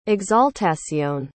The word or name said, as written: exaltacion